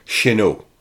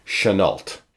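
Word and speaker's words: Chennault: Firstly, as I am recording this podcast in Montreal, Quebec, Canada, where there is a significant French population, I have always assumed that the good colonel's name was pronounced in the French manner, Chenault. However, in researching this episode, I have learned that, being an American, the colonel's family pronounced it in the Americanized way, Chenault.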